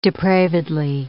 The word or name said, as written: depravedly